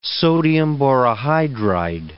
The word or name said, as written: sodium borohydride